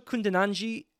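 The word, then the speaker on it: Kundananji: And Rachel Kundinanji does have the final vowel pronounced at the end of her name.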